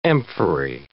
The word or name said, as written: amphorae